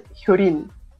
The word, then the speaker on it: Hyo Lin: Mi, la mía es Hyurin,